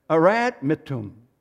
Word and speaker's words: Adramyttium: It's pronounced A-D-R-A-M-Y-T T-I-U-M. Arad Mittum.